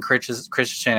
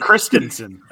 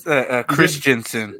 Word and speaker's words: Christensen: Yeah, I think you just mispronounced his name. That's why. – What is it? Sorry. Is it? How do you say it? – Christensen. – Christensen. What did I say? – It's Christensen. – Oh, shoot.